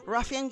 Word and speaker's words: Ruffian: Ruffian Games era algo similar. – Te confirmo, te confirmo que está bien pronunciado, Ruffian.